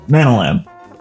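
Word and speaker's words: Nanalan': Nanoland, as in like the land of Jewish grandmothers? – Yes, actually, that is how it's pronounced. But, Nana? – Yeah, Nanoland.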